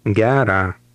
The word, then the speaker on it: Gera: Gera. – Did you stress the first part of word number 1?